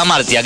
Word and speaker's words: Hamartia: Esto fue No Nos Queda Otra, el programa de la revista Amartya, que se escribe con H y se despide diciendo púliese, púliese, púliese.